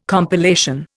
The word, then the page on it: compilation: Indian